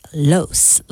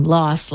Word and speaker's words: Los: Jamie Lee pronounces it Los, like rhymes with gross. Mariska is team Los, like boss.